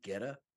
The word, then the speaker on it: goetta: It's called goetta. Please tell me I'm pronouncing that right. Yeah. – Yeah. – And you got it.